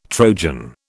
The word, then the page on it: Trojan: British English